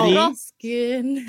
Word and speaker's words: Ruxpin: Is it Teddy Rupskin? How do you say it? Teddy Ruxpin.